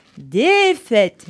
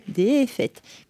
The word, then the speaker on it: Défaite: Allez, je vais commencer ma sélection éclectique de 2026 avec Défaite. Je le prononce comme ça parce qu'il y a deux accents aigus. Défaite.